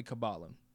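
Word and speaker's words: Qabbalah: So it's Q-A-B-B-A-L-A-H. – And it's not Kabbalah, right? We say Kabbalah because we're in the United States, but how would you pronounce it? – Kabbalah.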